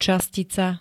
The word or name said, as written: častica